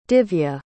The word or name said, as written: divya